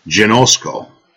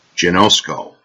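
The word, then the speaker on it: genosko (γινώσκω): The word in the Greek is pronounced genosko, genosko,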